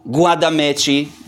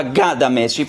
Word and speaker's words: Guadameci: L'altro termine è quello che abbiamo visto, Guadameci, in realtà Gadameci, perché in spagnolo G-U-A si legge Ga, concludo, e però lo troviamo anche in italianizzato nel Medioevo, Guadameci o Guadamesse, si riferisce appunto a questa cosa.